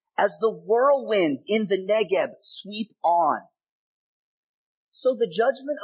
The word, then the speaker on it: Negev: And the Negev, now pronounced Negev, is a real place in the southern part of Israel.